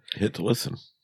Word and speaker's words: Hitlisten: What does that say? Hitlerstein? Am I mispronouncing that? – What? – Oh. On the Danish albums chart, number 14. – Hitlisten. – Oh, Hitl. – Hitlisten. Tracklisten.